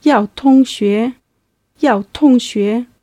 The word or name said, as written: yaotongxue